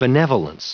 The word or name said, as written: benevolence